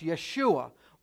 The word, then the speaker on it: Yeshua: It's pronounced Yeshua or Joshua.